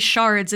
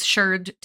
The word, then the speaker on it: sherd: We use sherd to mean pieces of pottery, and it is generally pronounced sherd instead of shard. So it's S-H-E-R-D. It rhymes with nerd because archaeologists are, and it really does specify pottery.